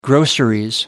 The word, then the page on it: groceries: British English